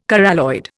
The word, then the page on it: coralloid: Indian